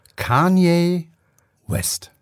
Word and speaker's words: Kanye West: Es wird wirklich ausgesprochen, Kanye West.